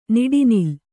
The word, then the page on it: ನಡುನಿಲ್: naḍu nil